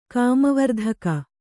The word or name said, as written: ಕಾಮವರ್ಧಕ